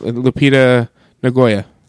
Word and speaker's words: Lupita Nyong'o: Lupita Nyong'o. – Yeah, something like that. – It's that. It's Lupita Nyong'o.